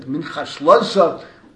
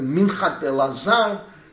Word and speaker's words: Minchat Elazar: the Minchat Elasa, the way they pronounce it, Minchas Elasa, but the Minchat Elazar, Sfarim that are quoted until today, a Posek Mufak.